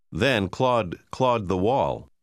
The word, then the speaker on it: Claude: Pronunci l'ultima parola come se non ci fosse una E finale. – My cat's name is Claude.